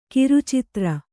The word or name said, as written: ಖರ್ಚುದಾರ